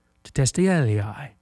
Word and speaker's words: tetelestai: It's a Greek word, and that Greek word is spelled T-E-T-E-L-E-S-T-A-I, tetelestai, is a very common word in the time of Jesus.